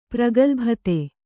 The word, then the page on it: ಪ್ರಗಲ್ಭತೆ: pragalbhate